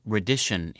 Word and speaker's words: reddition: English speakers also once adopted the French word reddition itself, pronounced reddition in English, and meaning either restitution, surrender, or elucidation.